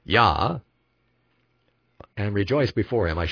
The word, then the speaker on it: Jah: Of course, the J came 500 years ago, so it in the modern scriptures, including King James, it says Jah, J-A-H. But we pronounce it Yah.